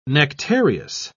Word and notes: nectareous: British English

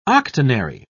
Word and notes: octonary: British English

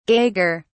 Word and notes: gager: British English

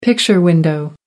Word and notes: picture window: American English

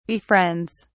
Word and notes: befriends: British English

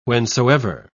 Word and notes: whencesoever: British English

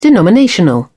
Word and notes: denominational: British English

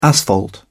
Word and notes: asphalt: British English

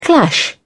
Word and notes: clash: British English

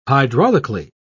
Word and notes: hydraulically: British English

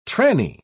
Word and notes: tranny: British English

